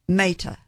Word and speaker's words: Mehta: How do you say Zubin's last name? – Mehta.